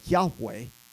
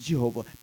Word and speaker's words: Yahweh: But anytime you see all caps, all uppercase, that is the name of God, the name of God, Yahweh, or sometimes pronounced Jehovah, but it means I am.